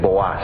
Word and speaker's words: Booz: Tenía Noemí un pariente de su marido, hombre rico de la familia de Elimelec, el cual se llamaba Booz. En el hebreo la pronunciación es Boaz. Y si ustedes me permiten, yo voy a pronunciar Booz porque me he acostumbrado así mejor.